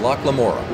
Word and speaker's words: Locke Lamora: The Lies of Locke Lamora. – Locke Lamora.